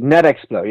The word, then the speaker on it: NetExplo: So, you're a bit of a technophile, I suppose, and you actually did a presentation at NetExplo. Is that how you pronounce it? – Indeed, NetExplo, yes, an observatory out of France.